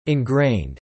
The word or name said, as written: ingrained